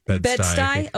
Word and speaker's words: Bed-Stuy: And then the Partridge Family is going to focus on a black family from Brooklyn's Bed-Stuy neighborhood. I might be saying that wrong. That operates Bedford-Stuyvesant. – Bed-Stuy. Okay. S-T-U-Y.